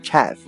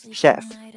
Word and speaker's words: chef: 那么刚才有提到的这个 caterer 啊 caterer，他呢其实后来受雇于这种店铺，慢慢的他就变成了一种人，叫做 chef chef chef。作为名词呢，就是厨师、主厨或者是大厨的意思。那么在这里呢，一旦提醒大家一下啊，这个单词不读作 chef 啊，虽然写成 ch，但是呢发成 sh 的音啊，叫做 chef。